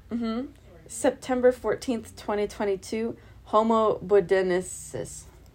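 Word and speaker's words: bodoensis: Homo bodenensis. – I think she nailed that pronunciation.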